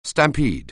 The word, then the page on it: stampede: anglais